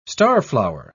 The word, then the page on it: starflower: British English